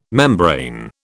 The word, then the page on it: membrane: British English